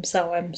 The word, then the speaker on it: Samhain: Yeah, I do pronounce it Samhain, so there you go. I was in the right, but anyway, but it's a very, very old holiday.